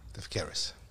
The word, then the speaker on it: Steph Keris: Steph Keris. – Keris. – That's right. – All right. So, unsurprisingly, it's pronounced just as it's spelled.